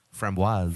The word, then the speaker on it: framboise: Framboise.